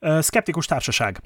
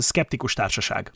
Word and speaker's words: Skeptikus Társaság: Or how would you pronounce it in Hungarian? – Hungarian Skeptic Society. Oh, Skeptikus Társaság. – Yeah, we don't call it Hungarian in Hungarian. We just call it Skeptic Society. – Yeah. – So it's Skeptikus Társaság.